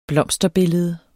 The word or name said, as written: blomsterbillede